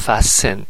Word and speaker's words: fasten: fasten تی تلفظ نمیشه